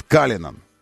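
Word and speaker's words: Cullinan: И он называется Каллинан, и пишется тоже Каллинан. Но они произносят Каллинан.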